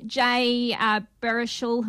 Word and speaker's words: Baruchel: And you've got Jay Baruchel, who I can never say his name. – You did. Very well.